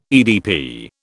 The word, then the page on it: EDP: British English